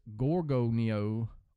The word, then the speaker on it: Gorgonio: Like, I looked up how to pronounce it, and it says Gorgonio or Gorgonio. I'm just going to say Mount G.